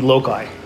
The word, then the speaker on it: loci: A lot of people say loci. There's no soft C in Latin, so it would be loci. And in fact, because it's spelled L-O-C-I-I, it's loci. Okay, I took Latin for 4 years, and I'm kind of proud of it, okay?